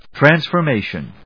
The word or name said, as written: Transformation